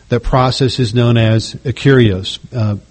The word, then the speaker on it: akeraios: The Greek word translated harmless in Matthew 16:10 is akarios. It's spelled A-K-E-R-A-I-O-S, akarios.